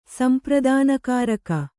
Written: ಸಂಪ್ರದಾನಕಾರಕ

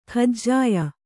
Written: ಕಜಿಯಾ

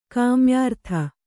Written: ಕಾಮ್ಯಾರ್ಥ

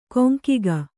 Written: ಕೊಂಕಿಗ

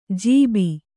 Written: ಜೀಬಿ

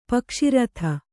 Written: ಪಕ್ಷಾರ್ಥ

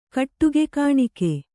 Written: ಕಟ್ಟುಗೆಕಾಣಿಕೆ

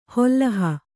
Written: ಹೊಲ್ಲಹ

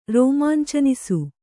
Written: ರೋಮಾಂಚನಿಸು

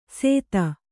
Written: ಸೇತ

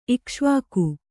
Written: ಇಕ್ಷ್ವಾಕು

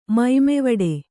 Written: ಮೈಮೆವಡೆ

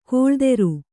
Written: ಕಳಾಧರ

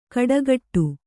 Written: ಖಡಾಖಡಿ

